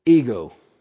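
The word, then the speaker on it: ego (ἐγώ): Epsilon Gamma Omega is pronounced ego.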